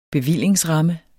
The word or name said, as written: bevillingsramme